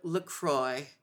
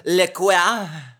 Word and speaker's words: LaCroix: You said it right. We were just talking about her last name is a litmus of how fancy someone is, because sometimes people are like, Le Quoi? Le Quoix? Or LaCroix, if you're, really not fancy.